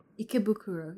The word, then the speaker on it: Ikebukuro: We will discuss with you many, many things like our time in Kabukicho and Shinjuku, and also Ikebukuro. Is that right? – Ikebukuro, yeah. – Yeah. Mm. Thank God I said that right.